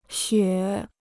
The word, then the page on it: 雪: xuě